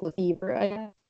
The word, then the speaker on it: Lefebvre: It's Lord Justin Lefebvre, which I guess for me to read as Bieber, like Lefebvre.